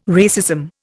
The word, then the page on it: racism: Indian